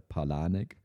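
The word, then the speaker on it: Palahniuk: Er wird anders geschrieben, als er ausgesprochen wird, aber ausgesprochen wird er Palanek.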